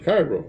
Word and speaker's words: Cairo: He gave me his name and address, and he lived in Cairo, Illinois, and which I pronounced Cairo, of course.